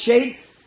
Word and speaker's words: shad: The really, the word, the Hebrew word Shad, S-H-A-D, some pronounce it Shade.